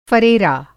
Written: फ़रेरा

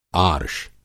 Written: आर्ष

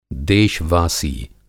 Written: देशवासी

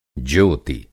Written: ज्योति

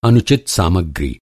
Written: अनुचित

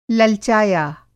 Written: ललचाया

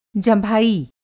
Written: जँभाई

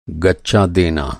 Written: गच्चा

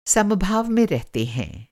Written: समभाव

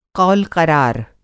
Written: क़ौलक़रार

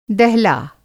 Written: दहला

